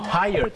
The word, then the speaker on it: tired: เหนื่อยนะครับอย่าลืมครับออกเสียง tired สองพยางค์นะครับ T-I-R-E-D ไม่ใช่ try รอเรือแล้วก็ไม่ใช่ไทยพยางค์เดียวนะฮะ